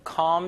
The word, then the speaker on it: calm: L 발음은 묵음입니다. calm, calm 안 돼요? calm music, calm music.